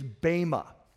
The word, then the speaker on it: bema: The word is bema. And in English, you spell it B-E-M-A, bema.